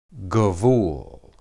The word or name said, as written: gebul